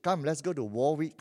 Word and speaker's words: Warwick: It's called Warwick. Don't ask me why it's pronounced Warwick. This English is very tough. The spelling is Warwick.